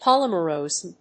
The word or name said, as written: polymersomes